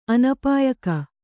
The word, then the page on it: ಅನುಪಯೋಗ: anupayōga